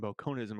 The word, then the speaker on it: Bokononism: we were talking about how to actually pronounce Bokonin and Bokoninism. And apparently it is pronounced Bokonin and Bokoninism, according to Kurt Vonnegut himself.